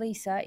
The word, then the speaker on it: Leesa: My name's Lisa. It's not spelt the traditional way of L-I-S-A, but instead it's spelled L-E-E-S-A.